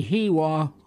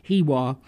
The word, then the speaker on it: Heiwa: As I was saying, it's the Hiwa Hoedown. I hope I'm pronouncing it right. It's spelled H-E-I-W-A. Hiwa Hoedown.